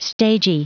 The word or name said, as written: stagy